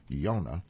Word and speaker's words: Yonah: Well, an article headlined Jonah and the Stormy Sea, or if you want to pronounce it properly in the Hebrew language, Yonah, the J is silent.